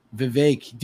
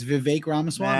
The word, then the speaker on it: Vivek Ramaswamy: That's his name. Vivek. Dude, he went on Tim Pool and he told them how to say his name. It's Vivek Ramaswamy.